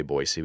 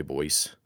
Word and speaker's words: Boise: And we say Boise, we don't say Boise, like Boise City in Oklahoma, for example. We say Boise. If you say it wrong, you're not from Idaho.